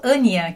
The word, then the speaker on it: Anja: E aí ele acabou conhecendo Ania, que se escreve Anja, né? Mas em alemão o J tem som de I.